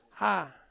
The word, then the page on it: a: Ansus